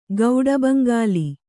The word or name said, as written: ಗೌಡಬಂಗಾಲಿ